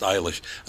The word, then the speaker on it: Eilish: It doesn't say how they pronounce it, but her name is pronounced Eilish. Another guy, K-E-L-C-E. How would you pronounce that? – Kelsey? – Kels. – K-E-L-C.